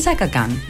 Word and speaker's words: Chaka Khan: Chaka Khan.